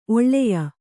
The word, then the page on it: ಒಳಾಯ: oḷāya